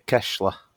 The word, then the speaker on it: Keshla: FK Keshla. – Keshla. I seen the accent on the S, and I knew if I go straight in with the hard S, I'm going to mess it up here.